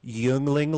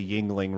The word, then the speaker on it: Yuengling: We have a friendly bet of one case of the pride of Pennsylvania for David, Yuengling Lager. Did I say that correctly? – All right, hold on. You got to pronounce that right. You're being offensive now. It's Yuengling.